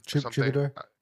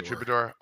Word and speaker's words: Troubadour: Troubadour. – Yeah, Troubadour.